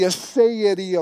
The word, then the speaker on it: aseity: It's called the aseity of God. Aseity. Let me spell it so you can look it up later. A-S-E-I-T-Y.